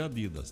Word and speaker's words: Adidas: Como é que você pronuncia? – Sim. – Aqui pra nós é Adidas.